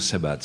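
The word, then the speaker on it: Sebhat: Il suffit de dire Sebat simplement. Sebat.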